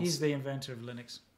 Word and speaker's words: Linux: I'm Linus Torvalds, and I pronounce it Linux.